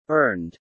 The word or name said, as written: earned